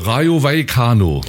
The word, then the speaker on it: Rayo Vallecano: Rayo Vallecano. Nicht Radio Vaticano, sondern Rayo Vallecano. So ungefähr.